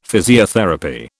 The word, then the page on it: physiotherapy: British English